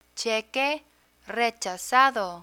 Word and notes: cheque rechazado: spanish

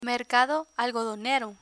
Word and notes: mercado algodonero: Spanish

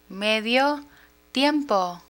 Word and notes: medio tiempo: Spanish

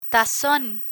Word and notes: tazon: spanish

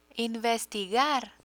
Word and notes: investigar: Spanish

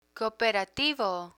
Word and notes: cooperativo: Spanish

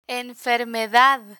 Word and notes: enfermedad: Spanish

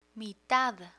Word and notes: mitad: Spanish

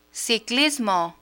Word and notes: ciclismo: spanish

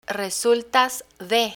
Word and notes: resultas de: spanish

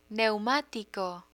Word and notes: neumatico: Spanish

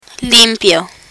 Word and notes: limpio: Spanish

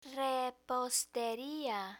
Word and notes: reposteria: spanish

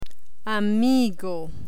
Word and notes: amigo: spanish

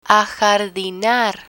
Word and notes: ajardinar: spanish

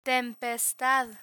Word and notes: tempestad: spanish

